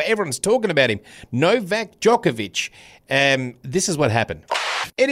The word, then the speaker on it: Djokovic: Novak Djokovic.